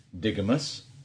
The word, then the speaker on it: digamous: The adjective for today's noun is digamous, which rhymes with bigamous.